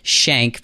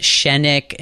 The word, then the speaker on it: Schenck: My last name is spelled S-C-H-E-N-C-K. It's pronounced Schenk. People tell me it's the longest one-syllable word they've ever seen. I get Schenick and Schenk all the time.